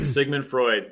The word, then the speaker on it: Sigmund Freud: Did I say it right? – Yeah, you said it right. – Sigmund Freud.